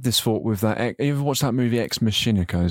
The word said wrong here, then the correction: Ex Machina: Is that how you say it? Machina? – Ex Machina.